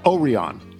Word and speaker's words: Orion: Orion is how it's pronounced, right? Orion.